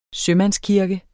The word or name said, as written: sømandskirke